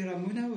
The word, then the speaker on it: Ramuna: for people just to say Ramuna without any accent or anything, it just...